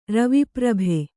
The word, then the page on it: ರವಿಪ್ರಭೆ: ravi prabhe